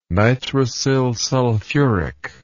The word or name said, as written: nitrosylsulfuric